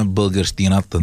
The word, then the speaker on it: българщината: Само ние защитаваме българщината. – Българщината, точно. Благодаря ти за правилното ударение.